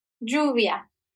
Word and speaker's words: lluvia: Lluvia. – Lluvia. Lluvia. Okay. So I said it, I pronounced it correctly, however Americanized I did.